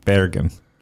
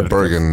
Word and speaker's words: Bergen: He was an exchange student in Norway, outside Bergen, or do you pronounce it Bergen? – I say Bergen, but yeah, Bergen in English, absolutely.